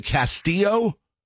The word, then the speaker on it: Castillo: It's spelled C-A-S-T-I-L-L-O. Important to note the T-I-L-L-O. I've never seen that pronounced any way other than Castillo. They pronounce it Castillo.